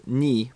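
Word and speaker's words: ni (二): Den, det, jag vet bara att det, det, är kattens dag för att två uttalas ni på japanska.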